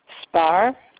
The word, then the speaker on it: Sparr: Spar. – S-P. How do you spell that? – S-P-A-R-R.